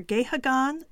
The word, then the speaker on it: Gahagan: And the woman who played she is Helen Gahagan or Gahagan Douglas. I'll spell that middle name, her maiden name, G-A-H-A-G-A-N Douglas.